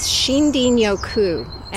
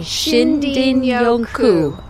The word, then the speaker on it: Shinrin-yoku: And the term in Japanese is Shinrin-yoku. – Say that one more time for me. – Shinrin-yoku.